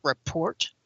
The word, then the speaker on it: Rapport: It's called, and the system is called Rapport. Oh, okay. I didn't realize that was a silent T.